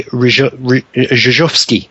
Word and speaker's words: Rzeszowski: So Poland's been off the calendar on the ERC for a couple of years, but we went down to this new place called Rzeszów, and it's taken me all week to be able to pronounce the name of it, even though you look at the spelling of it, it is nothing like the way you pronounce the rally name. So it's Rally Rzeszowski, I think, is the correct way to say it.